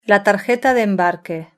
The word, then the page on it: la tarjeta de embarque: European Spanish